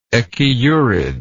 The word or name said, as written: echiurid